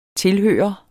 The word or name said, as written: tilhører